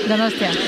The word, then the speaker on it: Donostia: How do I say it? Donostia. – Donostia.